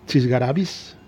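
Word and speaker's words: chisgarabís: Bueno, pues me ha recordado cuando buscaba la pronunciación correcta para la palabra esta que me ha sonado un poco extravagante, Chisgarabis, el acento en la I.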